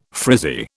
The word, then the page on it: frizzy: British English